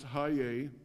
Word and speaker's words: chayay: Strong's number 2425 for life, L-I-F-E. It's actually translated from the word C-H-A-Y-A-Y, pronounced Haye.